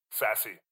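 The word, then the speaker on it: Sasse: Is that how you pronounce his name? Sass? Sassy. Apologies. – Yeah. Sassy. Ben Sassy.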